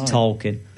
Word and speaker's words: Tolkien: We're both pronouncing it differently. – It's Tolkien.